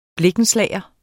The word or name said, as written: blikkenslager